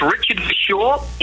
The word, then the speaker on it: Richard Verschoor: Well, this young gentleman, his name is Richard Verschoor. Am I saying that right? – Correct. It's Richard Verschoor.